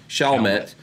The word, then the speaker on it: Chalmette: She posted a series of images of herself checking in to St. Bernard Parish Hospital in Chalmette I don't know how you say it. – Chalmette, Louisiana, in an effort to remove the She's just milking this.